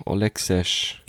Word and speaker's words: Olexesh: Wie schreibt man das? – O-L-E-X-E-S-H. Olexes.